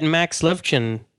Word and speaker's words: Levchin: So I noticed that Max Levchin, is that how I pronounce his name correctly? – Yep.